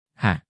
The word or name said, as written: h